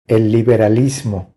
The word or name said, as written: El liberalismo